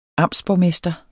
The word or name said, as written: amtsborgmester